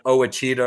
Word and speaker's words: Ouachita: It's spelled O-U-A-C-H-I-T-A. So you would not have, you would have thought it was called Ouachita or something.